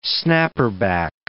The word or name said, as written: snapper-back